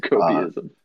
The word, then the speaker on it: copium: I've heard so much fucking copyism. One of my best friends. – Copium. – It's actually, I said it wrong. It's copium. My friend Owen.